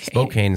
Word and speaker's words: Spokane: Spokane is how you say it.